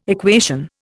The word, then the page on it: equation: Indian